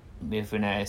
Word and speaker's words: Mifune: Almost all his films star the actor Toshiro Mifune, I think is how you say his name. – Mifune.